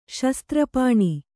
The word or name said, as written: ಶಸ್ತ್ರಪಾಣಿ